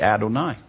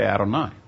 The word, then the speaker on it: Adonai: Up there in the Shema, it is actually the Yod Heh Vav Heh in the written scripture, but when we pronounce it, we say specifically Adonai. Verbally, we say Adonai.